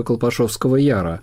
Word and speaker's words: Колпашевского Яра: Все наши слушатели знают историю Колпашевского Яра. – Колпашевское, правильное ударение. Да, да.